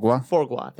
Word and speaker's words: foie gras: Foie gras.